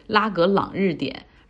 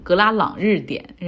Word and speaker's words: 拉格朗日点: 我这个文稿里写的是拉格朗日点，然后我能读成格拉朗日点，然后以至于我自己读的时候也觉得，哎，这个地方好像跟西藏的某个地名很相近。